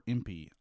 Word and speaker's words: Impe: Van Imp, or Impey, I-M-P-E is how you say his name.